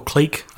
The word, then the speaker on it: clique: Would you say click or clique? I've heard it pronounced different ways before. Yeah, click, yeah.